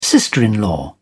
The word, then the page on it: sister in law: British English